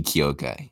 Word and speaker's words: Kyokai: And that's how you say my name, like Tokyo, but take out the toes. Cut off the toe, have Kyo, and then you have Kai, and then Tokyo, Kai.